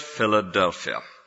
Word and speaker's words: Philadelphia: Greek word, Philadelphia. Not pronounced like that in Greek, but that's its modern pronunciation.